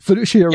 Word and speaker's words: fiduciary: What is fiduciary? Uh, did I pronounce that right? Fiduciary yeah, duties. Fiduciary. Yeah.